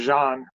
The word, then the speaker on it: Jean: And during that time, Jean, I think his name is pronounced Jean. Jean, it's like French.